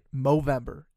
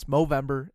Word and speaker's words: Movember: Movember. It's Movember.